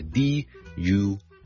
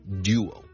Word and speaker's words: duo: 如果係英式英文發音呢，前面呢係一個 du，好似一個 d 音加個 u 咁，諗埋一齊變成 du du du。